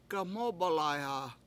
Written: gaMOOb1layha

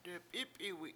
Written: dep7Ip7iwi7

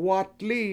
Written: watlII

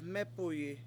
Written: MEpuyi

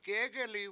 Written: gEgeli7

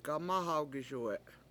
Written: gamAhawgiSuwe7

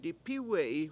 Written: dipIwe7i

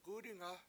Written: gUdinga

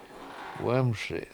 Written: wEmSi

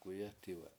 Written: k7uyE7tiwe7

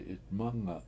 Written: 7itmAnga7

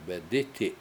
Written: bedIti7

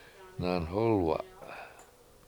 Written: nanhOlwa

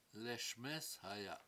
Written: leSmEEshaya7

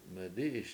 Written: medI7iS

